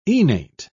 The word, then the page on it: enate: British English